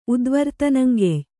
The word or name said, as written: ಉದ್ವರ್ತನಂಗೆಯ್